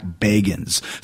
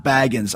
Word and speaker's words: Bagans: It's not Zach Bagans like Bilbo Baggins. It's Zach Bagans.